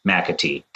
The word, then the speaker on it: McAtee: Hosted by our man, Riley McAtee.